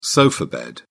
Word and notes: sofa bed: British English

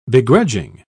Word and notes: begrudging: British English

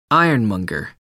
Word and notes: ironmonger: American English

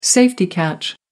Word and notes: safety catch: American English

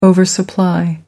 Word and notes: oversupply: American English

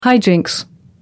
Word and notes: high jinks: American English

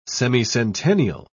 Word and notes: semicentennial: British English

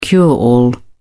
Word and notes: cure-all: British English

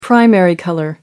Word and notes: primary color: British English

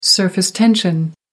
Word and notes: surface tension: American English

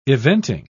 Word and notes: eventing: British English

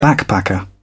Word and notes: backpacker: British English

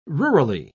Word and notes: rurally: British English

